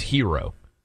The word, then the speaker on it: gyro: G-Y-R-O. If you say hero, people will be like, they have no idea. You got to say gyro, even though it's not pronounced correctly.